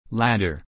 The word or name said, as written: ladder